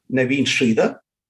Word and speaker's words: Naveen Sridhar: Yes, I got a website, and it's um, www and my name is uh, one one word, Naveen Sridhar.